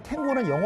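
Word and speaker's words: tango: 참고로 말씀을 드리면 탱고는 영어식 발음이고요. 스페인어로는 땅고.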